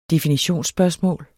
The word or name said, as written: definitionsspørgsmål